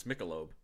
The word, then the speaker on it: Michelob: You were closer the first time. It's Michelob. – Michelob.